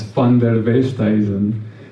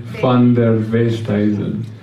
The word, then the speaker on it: Van der Westhuizen: So my name is PD, and my surname, which I am sure most of you may have seen and been like, how do you say that? it's Van der Westhuizen. So yes, again, Van der Westhuizen.